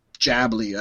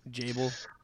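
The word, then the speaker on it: Jabil: And no, I was not mispronouncing Studio Ghibli. It's Jabli. I don't know what it is. It's J-A-B-I-L. – Jable.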